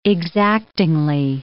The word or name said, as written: exactingly